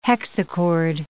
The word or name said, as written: hexachord